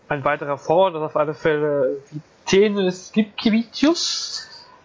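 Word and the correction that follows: Vytenis Lipkevičius: Oder wie die Aussprache richtig geht. Ja, Vitenis Lipkevicius, genau.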